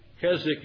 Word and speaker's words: Keswick: Keswick is spelled K-E-S-W-I-C-K, but it is pronounced without the W.